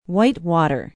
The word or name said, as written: Whitewater